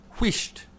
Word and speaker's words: whisht: But in it's pronounced whisht, and it does indeed mean be quiet. Oh, whisht, whisht there, for God's sake, stop talking. That's how you say it.